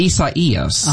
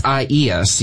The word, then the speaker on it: Isaias: I saw the name Isaias. – Actually, it's pronounced Isaias. – Oh, thank you for correcting me. – Isaias, yes. That is the official pronunciation from the Hurricane Center.